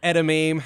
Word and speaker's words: edamame: Some edamame.